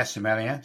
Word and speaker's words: sommelier: Yeah, sommelier.